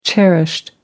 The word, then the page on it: cherished: British English